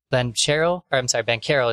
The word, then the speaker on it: Banchero: There was a point where it was Banchero is how you say his name.